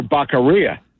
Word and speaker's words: Becerra: Well, you mean Javier Becerra, as Joe Biden would call it. – There you go. – Which rhymes with diarrhea.